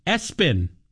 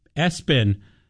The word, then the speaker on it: ESPN: Someone named their kid ESPN, pronouncing it Espen. Espen.